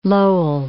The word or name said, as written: Lowell